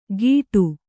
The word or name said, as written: ಗೀಟು